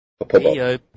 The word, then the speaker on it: Poppopo: No. Popobo. Popobo. P-O-P-P-O-P-O. Long O. Popobo.